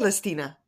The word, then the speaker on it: Celestina: Celestina. Because you pronounce the C with an H if you're mm-hmm. Italian.